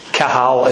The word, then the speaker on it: qahal: The Hebrew word that's used there for gathering people together is called kahal. It's spelled Q-A-H-A-L.